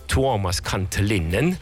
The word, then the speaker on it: Tuomas Kantelinen: En toen vond ik gelukkig, leven het internet, een Finse componist, Tuomas Kantelinen. – Wauw, wat spreek je dat goed uit. – Ja, Tuomas en Thomas.